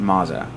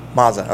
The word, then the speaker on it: Maza: They pronounce it Maza. – Maza.